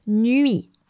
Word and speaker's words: nuit: And I draw your attention on the last word of the sentence, which should have been nuit. Night, and it's spelled N-U-I-T, and S for the plural.